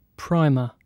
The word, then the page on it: primer: British English